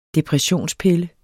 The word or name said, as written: depressionspille